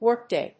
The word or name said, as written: workday